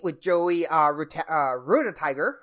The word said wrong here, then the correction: Rudiger: I think it's pronounced. – Rudiger, thank you.